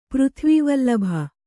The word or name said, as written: ಪೃಥ್ವಿವಲ್ಲಭ